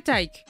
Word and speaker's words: Taiki: C'est Taiki parce que c'est comme Mike, mm -hmm. – mais c'est Taiki. – Donc nous les anglophones on dit Taiki. We don't know how to pronounce it. – Donc c'est dur. Taiki. Merci. – Et c'est dur. Taiki. Okay. Yes. – Yeah. – Comme so Mike. I like, I like Taike's music.